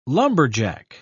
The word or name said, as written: lumberjack